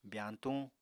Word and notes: byañtuñ: apatani

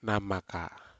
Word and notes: namaka: Aneityum

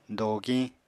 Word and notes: dogiñ: apatani

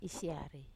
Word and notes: siar: Wamesa

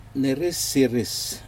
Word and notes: neriseris: Aneityum

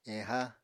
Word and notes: eha: Apatani

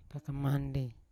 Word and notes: kangkamandi: Yawa

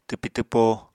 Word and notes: twpi-twpo: apatani

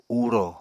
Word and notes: uro: Apatani

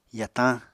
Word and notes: yatañ: apatani

